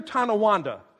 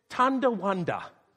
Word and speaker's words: Tonawanda: Now, I don't know what it is about that particular name, but when they hear it, when they hear Tonawanda, especially little kids, they want to add a letter. They want to add a D. They want to pronounce it Tondawanda. Tondawanda. And as a little guy, that's exactly what I did. I thought I lived in North Tondawanda, New York.